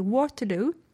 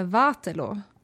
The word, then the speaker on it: Waterloo: Waterloo. – Ja. – Så som engelsmännen uttalade. – Mm. – Istället för Waterloo, som det egentligen heter.